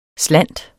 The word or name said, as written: slant